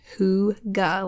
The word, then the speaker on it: hygge: So type in the word hygge, pronounced hygge, like I've been saying. Hopefully, that's the general consensus.